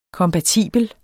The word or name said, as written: kompatibel